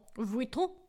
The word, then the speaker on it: Vuitton: Well, first of all, I know that we should be pronouncing it Louis Vuitton. – Vuitton.